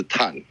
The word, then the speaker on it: Tuan: 如果是北京话是 Tan 啊，那如果是其实它是台语了哈，它叫做 Tuan，哎，试试看发音 Tuan 台湾。